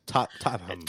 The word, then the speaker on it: Tottenham: Also said the stadium name wrong. It's Tottenham.